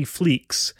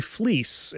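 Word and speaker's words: Fleecs: And I introduced him as Tony Fleeks. But letter C has two different sounds it can make. And he actually pronounces his name Tony Fleece.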